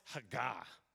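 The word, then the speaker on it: Haggah (הגה): That word meditate, very interesting. It's pronounced Haggah.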